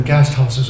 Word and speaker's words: Gasthaus: I says, if you'll just look for places like this, it's spelled G-A-S-T-H-A-U-S, and it's called Gasthaus. You look for a place called the Gasthaus, and that's normally has a restaurant and it has rooms that you can stay in.